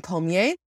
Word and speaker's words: Pommier: Next up is Jacqueline Pommier. I'm going to pronounce it the French way.